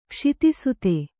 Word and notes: ಕ್ಷಿತಿಸುತೆ: Kannada

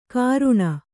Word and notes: ಕರುಣ: karuṇa

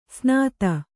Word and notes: ಸ್ನಾತ: snāta

